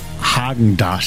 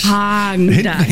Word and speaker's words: Häagen-Dazs: Spricht man das mit R oder mit A aus? – Man spricht es mit A aus. Ich sage auch, es heißt Häagen-Dazs. – Häagen-Dazs. – Mit ein bisschen genuscheln.